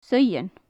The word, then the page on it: saoidhean: gaelic